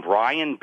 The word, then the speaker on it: Brian: It's my name, Brian, B as in boy, R-I-A-N. Last name is Peskin, P as in park, E-S-K-I-N.